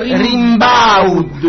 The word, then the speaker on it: Rimbaud: E come si pronuncia? – Fascisti. – Allora si pronuncia Rimbaud. Arthur Rimbaud.